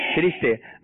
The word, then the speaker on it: فرشتے: فرشتے فرشتے، فا کے نیچے زیر ہے اور را کے نیچے بھی زیر ہے، تو جب بھی آپ کہیں تو کیا کہنا ہے آپ نے؟ فرشتے فرشتے. اس کی دہرائی کر لیں، درست تلفظ کیا ہے؟ فرشتے.